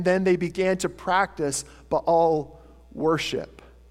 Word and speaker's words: Baal: Some of you maybe read that as Baal. The proper pronunciation is Baal.